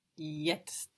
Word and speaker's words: Jetzt: Jetzt. – Good. You can think first of yet and then add your ST because this is a kind of complicated word.